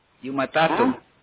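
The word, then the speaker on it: Jumatatu: It's J-U-M-A-T-A-T-U. Stands for Monday. And it goes. – You matatu. You're my tattoo. – Like Y. And it basically, or you could say that the J is silent in this instance. So it's you matatu.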